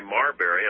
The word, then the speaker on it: Marbury: So he decided, his court decided in Mayberry versus I'm sorry, Marbury, I'm saying it wrong, Marbury versus Madison, that the power to overthrow the acts of Congress, establishing the concept of judicial review, he called it, was constitutional.